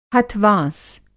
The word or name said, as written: हथ-वाँस